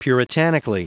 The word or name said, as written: puritanically